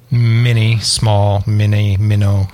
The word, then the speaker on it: Mino: No, it's Minnow. They Minnow. Made it Minnow. But they didn't spell it like M-I-N-N-O-W. They spelled it M-I-N-O, so people would say Minnow.